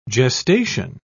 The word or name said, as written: gestation